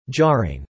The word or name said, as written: jarring